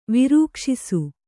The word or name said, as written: ವಿರೂಕ್ಷಿಸು